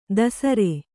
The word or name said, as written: ದಸರೆ